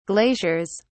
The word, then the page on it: glaciers: British English